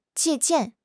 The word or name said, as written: 借鉴